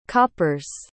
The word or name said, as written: coppers